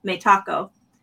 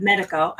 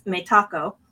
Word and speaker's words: Metaco: within that acquisition, if you look back just about 6 weeks ago, Metaco, which is how the company says it, by the way, if you go to the company website and listen to how they pronounce their company name, they pronounce it Metaco instead of Medico. I think Medico sounds, it rolls off the tongue easy for Americans and Canadians, but if you listen to how they pronounce it, it's Metaco.